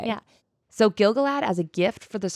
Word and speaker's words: Gil-galad: They were built in Second Age 600, and they were built by Gilgalad.